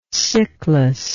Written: cyclas

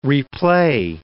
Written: replay

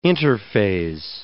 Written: interphase

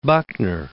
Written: Buckner

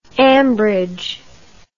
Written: ambridge

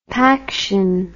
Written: paction

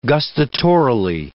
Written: gustatorily

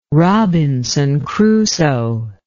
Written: robinson crusoe

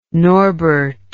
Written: norbert

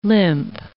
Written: limp

